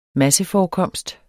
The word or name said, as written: masseforekomst